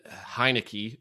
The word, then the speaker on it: Heinicke: But they said Rivera could rotate Smith and Heinicke. Is that how you pronounce his name? – Yeah.